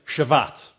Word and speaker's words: Shabbat: The Sabbath, the word comes, it's in Hebrew it sounds like Shabbat, right? The V and B were interchangeable. And so Shabbat is a closer Hebrew pronunciation.